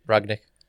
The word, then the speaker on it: Rangnick: Rangnick.